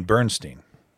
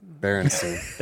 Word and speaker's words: Berenstain: You mean Bernstein? – I don't remember. – Berenstain. – That one. Exactly my point.